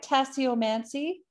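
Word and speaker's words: tasseomancy: Hopefully, did I say that right? Tassiomancy? Is that how you pronounce it? – Yes, perfect.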